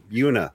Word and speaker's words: Buena: The people in Jersey, even though there's a heavy Puerto Rican population out there, they pronounce it Buna. – Buna was my high school.